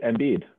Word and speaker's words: Embiid: Joel MVP Embiid. Is it a coincidence his last name rhymes with MVP?